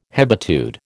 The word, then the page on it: hebetude: American English